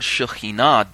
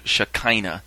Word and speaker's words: Shekhinah: The word Shekhinah does not, or Shekinah, as you've probably heard it pronounced in search circles.